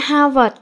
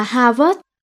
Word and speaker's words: Harvard: Nhiều người phát âm chữ Harvard là Harvard. Tony nghe không có hài lòng, nên đọc là Harvard nha. Vì chữ vợt nghe nó có tính chất là thể thao, kiểu như là quần vợt.